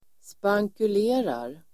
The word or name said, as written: spankulerar